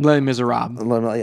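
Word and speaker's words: Les Misérables: International film feature, or feature film, Corpus Christi, Honeyland, Les Miserables. I don't know how you pronounce that. – Les Miserables.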